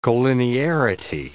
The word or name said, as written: colinearity